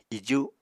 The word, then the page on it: iju: apatani